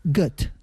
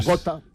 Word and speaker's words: Goethe: Y según he visto, se pronunciaría Goethe. – Mira, bueno, como Goethe.